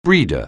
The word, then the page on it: breeder: anglais